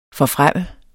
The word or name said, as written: forfremme